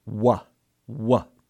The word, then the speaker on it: Waw: If you listen to how I pronounce it here, Wah, Wah, that's the sound. You can hear the shortened form of U, And so this letter sometimes represents that sound as a vowel in the Hebrew language. But it also stands in for the tightening of the mouth in that same form where we go, oh, oh, oh. So the long O sound as well.